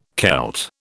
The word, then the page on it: Celt: British English